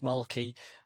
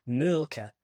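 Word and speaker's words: Mölkky: We use Mulkey. Most of the people we play against use Mulkey, but the Finns have a very unusual pronunciation, which, please forgive me, I'll try it. It's Mulka. So I think that's, something a bit more like that. But to us, it's Mulkey.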